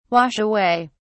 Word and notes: wash away: British English

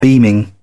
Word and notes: beaming: British English

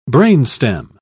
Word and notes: brainstem: British English

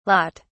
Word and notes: lat: British English

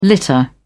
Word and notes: litter: British English

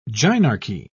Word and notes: gynarchy: British English

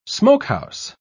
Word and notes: smokehouse: British English

